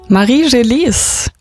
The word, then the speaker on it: Marie Gélis: Marie Gélis mit L'eau de la Fontaine. Alina, bist du stolz auf mich? Habe ich es halbwegs okay ausgesprochen? – Ja, perfekt.